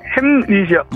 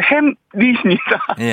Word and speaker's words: 햄릿: 1번 벌거벗은 임금님 2번 햄릿 3번 로미오와 줄리엣 자 여기에 15만원 상당의 가족사진 촬영권 동네 친구 30명의 선물이 걸려 있습니다 정답은 2번 햄님입니다 2번 뭐라고요? 햄릿이요 2번 햄님 햄릿이요 제 발음이 안 좋을 때 2번 햄릿, 햄릿.